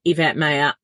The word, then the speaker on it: Yvette Mayer: As ever, I'm always available over on Instagram at Yvette Mayer _. My name is spelled YVETTEMAYER.